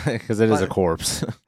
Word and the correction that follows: corps: Well, it's the core, dude. – But it's the abbreviation C-O-R-P-S, so... – Yeah, but the P is silent.